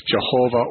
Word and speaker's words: Jehovah: And they took the vowels from the name Adonai and put them with the tetragram and came up with Jehovah or Yahweh. It can be pronounced either way, but Yahweh is somewhat more Hebrew sounding.